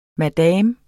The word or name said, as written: madame